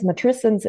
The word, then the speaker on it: Matrescence: Und dann bin ich auf den äh, Begriff Matrescence, Matrescence in schön Englisch ausgesprochen äh, gestoßen.